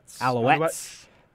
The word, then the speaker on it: Alouettes: Alouettes. – Montreal Alouettes he was with Burrow.